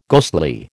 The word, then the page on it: ghostly: American English